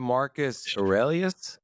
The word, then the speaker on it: Marcus Aurelius: So is it Marcus Aurelius? Am I saying that right? – Yes, sir.